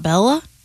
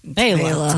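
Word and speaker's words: Béla: Born in 1958, the great banjo player, Mr. Fleck, first name B-E-L-A. – B-E-L-A. It's not Bella? – It's Bela. – Bela.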